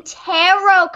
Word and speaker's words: tarot: No, I'm talking about the terrier cards, the cards with the little doggies on them. – Oh, you mean tarot cards. Oh, that's right. Yeah, I thought you were saying it wrong.